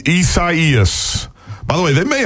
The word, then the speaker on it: Isaias: And and the big question, how do you pronounce it? Esaias or Isaias? – Esaias. – Esaias is definitely the Weather Channel's the Spanish pronunciation.